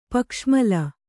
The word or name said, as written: ಪಕ್ಷಮೂಲ